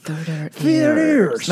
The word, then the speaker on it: Theater Ears: La TH a uno le cuesta. – Theater Ears. – Porque no es una... Nunca lo pronuncias en español. La TH en español no existe.